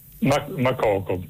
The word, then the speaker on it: Makoko: Mokoko, con l'accento? – Makoko, sì, non so se è con l'accento finale alla francese, Makoko, però probabilmente siccome quello è un paese anglofono sarà in maniera diversa.